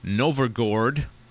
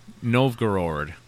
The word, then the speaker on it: Novgorod: So according to legend, a fearsome horde of Tatar horsemen were on their way back, or on their way to sack the city of Novgorod. No, I'm saying that wrong. – Novgorod.